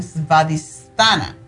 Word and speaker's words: Svadhisthana: Y en um, hindú le llaman svadistana Svadhisthana chakra. Así lo pronuncian.